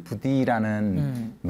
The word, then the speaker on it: Buddhi: 이상해서 약간 좀 I로 썼는데, 그럼 발음이 부디가 아니라 여전히 버디 같아요, 발음이.